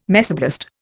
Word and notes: mesoblast: Indian

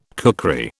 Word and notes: kukri: British English

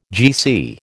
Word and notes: GC: American English

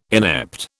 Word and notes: inept: British English

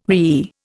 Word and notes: whee: Indian

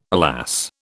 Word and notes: alas: British English

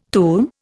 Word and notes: tum: Indian

